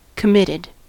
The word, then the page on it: committed: American English